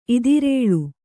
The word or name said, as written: ಇದಿರೇಳು